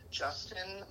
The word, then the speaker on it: Justin: So my name is Justin McCoy, so it's J-U-S-T-I-N, and last name's McCoy, so M-C-C-O-Y.